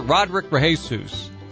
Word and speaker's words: Rodrick Rejesus: Joined by Jeff Turner, and we're joined by Dr. Rodrick Rejesus.